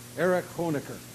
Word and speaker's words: Erich Honecker: I asked Rick Langer about how to pronounce his name earlier, and Rick, not knowing what I was going to be talking about, immediately said, because he had relatives who lived in East Germany, Erich Honecker, there was a man who was hated.